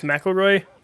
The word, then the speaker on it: McElroy: I've always understood it as McElroy. – Okay. So the accent on the first syllable.